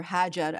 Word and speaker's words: Hajar: I'll say her name in Arabic and in English, you know, Hagar or Hajar, as we say in Arabic,